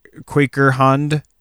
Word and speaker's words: Kooikerhondje: It's spelled K-O-O-I-K-E-R-H-O-N-D-J-E. But it's pronounced Quaker Hound, or Quaker Hound is like kind of the American.